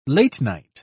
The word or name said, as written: late-night